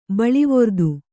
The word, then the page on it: ಬೀಳುವ್ರಿತ್ತಿ: bīḷu vritti; Kannada